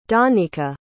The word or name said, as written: dawnika